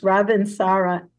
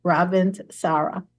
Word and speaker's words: Ravintsara: It's Ravinsara, R-A-V as in Victor, I-N-T, like Ravint, and then S-A-R-A, Ravinsara.